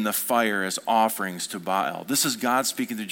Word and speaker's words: Baal: You know that they began to offer their children as sacrifices to the god Baal, or Baal is actually how it's pronounced.